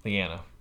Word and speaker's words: Lyanna: Lana. I can't say her name. – Lyanna. – Lyanna Mormont.